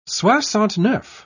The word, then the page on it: soixante-neuf: British English